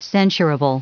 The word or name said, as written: censurable